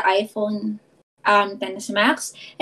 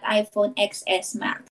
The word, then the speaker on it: iPhone XS Max: And then people were bashing me kasi I was pronouncing it wrong. It was supposed to be iPhone 10s Max but not iPhone XS Max.